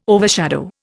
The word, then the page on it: overshadow: Indian